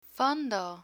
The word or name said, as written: fondo